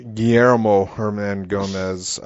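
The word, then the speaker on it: Guillermo Hernangómez: You've got Willie Hernan Gomez, who, I mean... – Say it right, Billy Hernan Gomez. – Guillermo Hernan Gomez.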